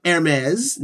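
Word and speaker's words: Hermès: E, clothing from Hermes. Notice I say it properly.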